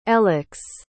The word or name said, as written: elex